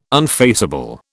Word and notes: unfaceable: British English

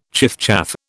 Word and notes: chiffchaff: British English

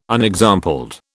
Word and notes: unexampled: British English

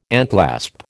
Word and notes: enclasp: American English

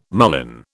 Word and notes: mullein: British English

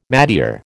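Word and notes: métier: American English

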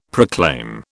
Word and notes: proclaim: British English

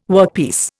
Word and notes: workpiece: Indian

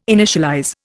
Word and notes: initialize: Indian

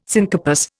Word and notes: syncarpous: Indian

